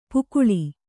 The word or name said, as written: ಪಕಳಿ